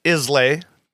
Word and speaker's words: Islay: So yeah, but that's what Islas are, and it looks like they're pronounced Islay. Islay, but yeah, Isla. – Like an island, but without the N-D.